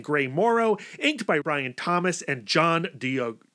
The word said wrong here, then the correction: D'Agostino: how do you say that last name? John D'Agostino, D'Agostino, colored by Barry Grossman and lettered by Gary Fields.